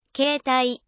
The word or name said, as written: 携帯